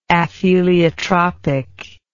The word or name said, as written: apheliotropic